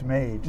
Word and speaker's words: Mage: Well, the favorite, Madge, looks, am I pronouncing it right? Madge? – I believe it's Mage.